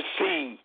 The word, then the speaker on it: See: The Western Church first initially developed through the auspices of the Holy See of Catholicism. Now, they pronounce, they spell S-E-E, but it sounds like the S-E-A see that's in season.